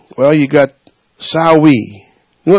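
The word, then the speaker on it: Samhain: A Sawi is spelled S-A-M-H-I-H-A-I-N, and say it again, S-A-M, Sam, H-A-I-N. And you're saying, Sawi is spelt that way? I say, yes, it's Celtic.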